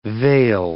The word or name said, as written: veil